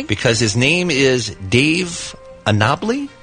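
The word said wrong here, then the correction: Annable: I think he pronounces it Annibal.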